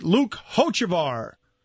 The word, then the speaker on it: Hochevar: Luke Hochevar. Is that how you pronounce his name? I always have trouble with his name. – You got it. – I don't know if I have that right, actually. – No, you got it.